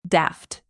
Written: daft

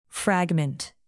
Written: fragment

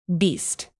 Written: beast

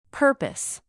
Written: purpose

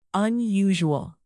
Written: unusual

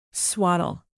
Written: swaddle

